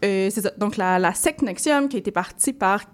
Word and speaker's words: NXIVM: qui s'écrit N-X-I-V-M, mais qui est prononcé Nexium.